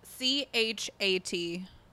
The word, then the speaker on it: Chat: His name was C H A T. – Chat. – Chat. – Chat. – They pronounce the C H. – Yeah, there's two CHs in Thai.